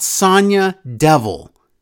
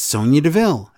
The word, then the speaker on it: Sonya Deville: It's not Sonya Devil. It's Sonya Deville.